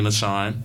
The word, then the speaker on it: Achane: Not Achane, Achane. I'm pretty sure that's how you pronounce it.